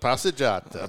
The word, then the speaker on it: passeggiata: And your passeggiata. So, and I'm glad I can. Did I say that properly this time? – Passeggiata. – Passeggiata. Yeah.